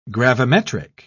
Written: gravimetric

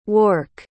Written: wark

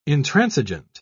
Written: intransigent

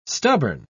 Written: stubborn

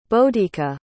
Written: bodhika

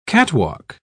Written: catwalk

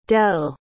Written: del

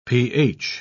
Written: ph

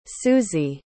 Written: suzie

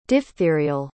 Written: diphtherial

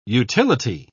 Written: utility

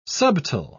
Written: subtile

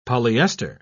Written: polyester

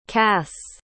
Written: kass

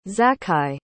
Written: zaccai

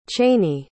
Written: cheyney

